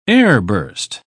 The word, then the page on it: airburst: British English